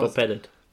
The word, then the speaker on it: Bob Pettit: ja, nee, ich, ich wollte die ganze Zeit dich schon fragen, wie spricht man denn aus? Bob Pettit. Pettit.